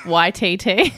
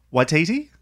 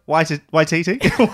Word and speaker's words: Waititi: When Liam said that you pronounce his last name like he's an android from Star Wars. YTT. – Taika Waititi? Is that better? – It sounds better, yeah.